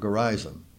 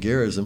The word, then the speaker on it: Gerizim: You might find it also pronounced Mount Gerizim. I looked it up one time, and either pronunciation is accepted. I've been saying Gerizim for so many years, it's hard to break that habit.